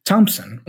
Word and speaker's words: Thompson: It is written TH, but we don't say Thomas. We say Thomas with a T, ta-ta, Thomas. Or Thailand, the country, also Thailand. Thompson, the name again, Thompson.